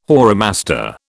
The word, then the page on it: whoremaster: British English